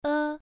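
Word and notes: e: tone 1

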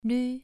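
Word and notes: lv: tone 2

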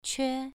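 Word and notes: que: tone 2

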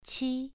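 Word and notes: qi: tone 2

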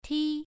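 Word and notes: ti: tone 1

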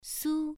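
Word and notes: su: tone 1

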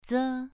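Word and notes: ze: tone 2

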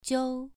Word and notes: jiu: 2

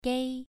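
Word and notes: gei: tone 2

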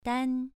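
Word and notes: dan: tone 2

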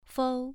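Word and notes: fou: tone 2

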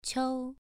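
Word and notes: qiu: tone 2

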